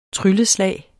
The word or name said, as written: trylleslag